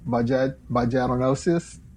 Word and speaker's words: vaginosis: In case you missed it, a gynecologist advised avoiding Paltrow's viral eggs because of potential health risks, and such as bacterial vaginosis. How do you say that? – Oh. – Vaginosis.